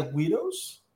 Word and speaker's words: Guido: Se escribe G-U-I-D-O, widow.